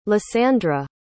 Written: lisandra

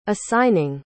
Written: assigning

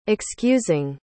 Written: excusing

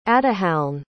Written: ata'halne

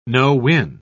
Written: no-win

